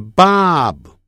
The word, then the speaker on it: Bob: 爸，但后头有一个啊，就变成 b，变成 bob 啊，变成 bob bob，不要念成 bob 那么强调啊，就 bob，但是通常是把它停顿 bob bob，这样就好了。